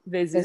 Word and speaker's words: Vizier: Like, how do you say that with a Turkish accent? – Vizier. Vizier.